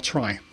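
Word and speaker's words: trie: And I said in the introduction that you can say the word tree as tree or try. It's T-R-I-E. And I specifically choose to pronounce it tree so that I don't get known as being both the catch guy and the try guy.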